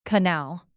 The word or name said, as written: canal